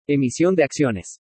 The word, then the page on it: EMISION DE ACCIONES: Spanish